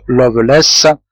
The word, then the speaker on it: Lovelace: Ce nom, qui se prononce Lovelace, appartient à de vieilles familles anglaises.